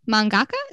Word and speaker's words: mangaka: So let's talk about our favorite mangaka.